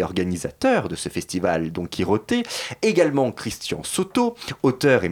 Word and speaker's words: Quijote: Le festival a son site, festival donc Quiroté, ça s'écrit en espagnol, Q-U-I-J-O T E c'est comme ça que vous le retrouverez sur Internet.